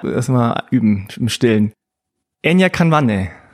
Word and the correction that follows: Kawande: Bis auf das N, das du ausgesprochen hast? – Ah, okay. Das ist ein stummes, in, also es ist Enya Kawande.